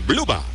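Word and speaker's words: Blubaugh: Y su apellido se pronuncia Bluba. Bluba. Se escribe Blubach con GH al final.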